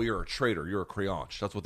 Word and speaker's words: creonte: It's spelled C-R-E-O-N-T-E, creonche.